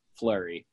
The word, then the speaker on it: Fleury: That is spelled F-L-E-U-R-Y, Flurry, named after the goaltender formerly of the Pittsburgh Penguins, personal bias.